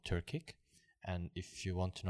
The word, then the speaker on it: Turkic: Turkic. – Turkic, exactly.